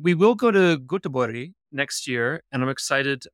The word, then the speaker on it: Göteborg: In Swedish, it's Göteborg. In English, it's Gothenburg.